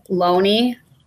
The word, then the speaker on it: Loney: I had Maggie Loney. I remember that her name is Loney because it rhymes with baloney.